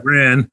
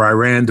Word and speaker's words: Iran: Iran, or Iran, depending on your pronunciation.